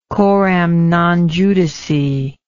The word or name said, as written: coram non judice